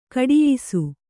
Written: ಘಟಿಯಿಸು

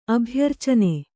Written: ಅಭ್ಯರ್ಚನ

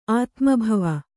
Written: ಆತ್ಮಭವ

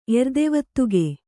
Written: ಎರ್ದೆವತ್ತುಗೆ

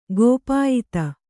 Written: ಕಿಪಾಯತ

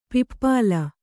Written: ಪಿಪ್ಪಾಲ

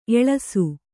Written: ಎಳಸು

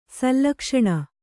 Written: ಶ್ಲಕ್ಷ್ಣ

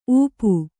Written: ಊಪು

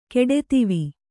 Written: ಕೆಡೆತಿವಿ

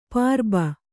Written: ಪುರ್ಬು

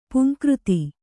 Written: ಪುಂಕೃತಿ

